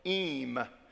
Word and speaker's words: Eyam: He said, there's a story about a village called Eyme. It's spelled E-Y-A-M.